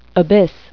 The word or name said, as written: abyss